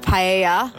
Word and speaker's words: paella: We actually got served paella. How do you say it again? – Paella. – Paella. – Paella. The double L is yeah.